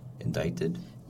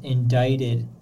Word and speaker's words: indicted: Indicted? – Yes, indicted. This word doesn't sound like the way it's spelled, so make sure you're careful with that.